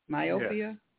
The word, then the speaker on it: myopia: Myopia? – Yes.